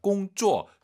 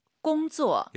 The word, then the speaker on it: 工作: 일하다 공쪼 쌍지읒하지 마시고. – 공조. – 약간 지은 느낌으로 힘 빼고 발음하시는 게 포인트입니다.